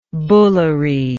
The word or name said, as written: bullary